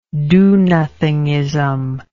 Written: do-nothingism

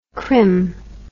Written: Krym

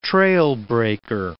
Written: trailbreaker

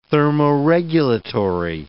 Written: thermoregulatory

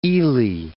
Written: Ely

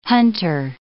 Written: hunter

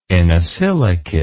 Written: inosilicate

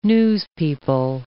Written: newspeople

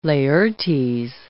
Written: Laertes

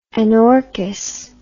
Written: anorchous

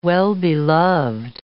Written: well-beloved

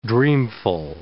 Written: dreamful

